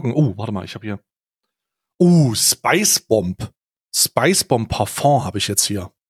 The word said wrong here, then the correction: Parfum: Parfeng heißt das, wird das ausgesprochen. – Parfeng habe ich jetzt hier.